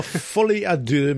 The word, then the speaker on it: folie à deux: Well, folie à deux means madness of two.